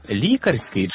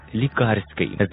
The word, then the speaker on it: лікарський: Як же правильно, лікарський чи лікарський? Зараз заглянемо до словника наголосів української мови за редакцією Миколи Погрібного. Лікарський говорять тоді, коли мова йде про лікаря, а лікарський – той, що має лікувальну цілющу дію.